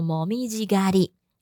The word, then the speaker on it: もみじ狩り: 比方说十一、十二月就是日本的这个红叶的季节，我们就可以说もみじ狩りの旬。好，红叶，然后狩后面写个狩猎的狩，哈，那我们念作もみじ狩り。